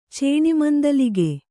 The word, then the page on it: ಚೇಣಿಮಂದಲಿಗೆ: Kannada